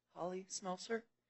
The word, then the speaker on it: Holly Smelser: Holly Smelser. – Could you spell that? – H-O-L-L-Y-S-M-E-L-S-E-R.